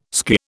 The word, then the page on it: skirr: British English